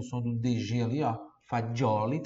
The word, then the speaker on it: fagioli: Que tá me assistindo no YouTube, no Instagram, no, no Facebook, Fagioli, que tá me ouvindo no podcast, F-A-D-G-I-O-L-I. F-A-D-G-I-O-L-I, como se pronuncia, né? Não tem esse D na hora de escrever, tá? É, é F-A-G-I, Fagioli, mas a pronúncia é como se tivesse um D ali, por isso fa... tem o D ali, né? Por isso na pronúncia tem o D. Fagioli, Fagioli, como em buongiorno, por exemplo, tá? Mas na hora de escrever, você vai escrever F-A-G-I-O-L-I, Fagioli.